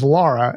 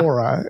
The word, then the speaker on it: Lara: When we talked about Lara Croft, her name is Lara, L-A-R-A, not yeah. Laura. And I said Laura a lot.